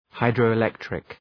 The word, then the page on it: hydroelectric: {,haıdrəʋı’lektrık}